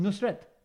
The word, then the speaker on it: Nusret: How do you say that? Nusret. – Yeah, not bad. It's just Nusret. – Nusret. – Yeah. – Nusret, like as in Fateh Ali Khan.